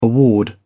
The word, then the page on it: award: British English